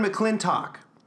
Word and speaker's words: McClintock: It's a brand new guest, brand new show, Catherine McClintock.